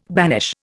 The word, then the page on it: banish: Indian